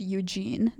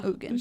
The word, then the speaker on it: Ugin: I feel the need to tell him that it's pronounced Eugene, not Ugin.